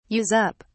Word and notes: use up: British English

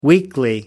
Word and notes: weekly: American English

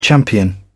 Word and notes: champion: British English

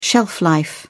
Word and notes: shelf life: British English

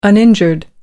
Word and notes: uninjured: American English

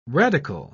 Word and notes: radicle: British English

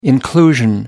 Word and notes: inclusion: British English

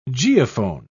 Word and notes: geophone: British English

